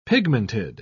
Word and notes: pigmented: British English

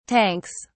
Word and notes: tanks: British English

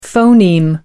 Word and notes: phoneme: American English

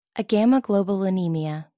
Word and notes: agammaglobulinemia: American English

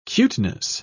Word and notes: cuteness: British English

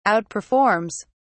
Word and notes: outperforms: British English

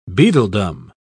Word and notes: beadledom: British English

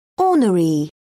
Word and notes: ornery: British English

